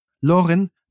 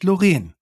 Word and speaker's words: Loren: Loren, nicht Loren. Betonung auf der ersten Silbe. Darauf legt sie Wert.